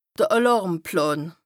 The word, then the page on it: d' Àlàrmplän: Bas Rhin